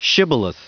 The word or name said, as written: shibboleth